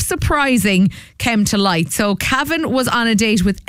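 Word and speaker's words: Kavan: It's K-A-V-A-N, which is either Kevin or Cavan. But if you say it in an English accent, Cavan.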